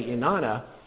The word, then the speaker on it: inanna: Now that I say inanna, even though it looks like ana on the page, the way we pronounce this is, in Aramaic, inanna.